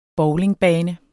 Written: bowlingbane

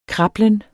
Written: krablen

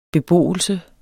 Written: beboelse

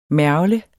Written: mergle

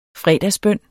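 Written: fredagsbøn

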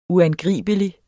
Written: uangribelig

